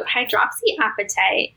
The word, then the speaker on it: hydroxyapatite: Yeah, so hydroxyapatite is actually an organic mineral that is in our tooth structure. So we have like 97% of our enamel is made of hydroxyapatite.